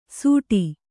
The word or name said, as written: ಸೂಟಿ